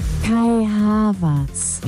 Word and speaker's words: Kai Havertz: Kai Havertz. – No, no, pero más rapidito. Es que así suena muy como... ¡Ay, tuviera pereza! – Kai Havertz.